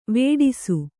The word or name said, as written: ವೇಡಿಸು